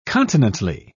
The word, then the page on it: continently: British English